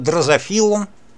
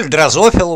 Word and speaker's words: дрозофила: Ну, в качестве примера возьмем плодовую мушку, нашу знаменитую дрозофилу, или дрозофилу, уж как больше нравится ударение ставить, я и так ставлю.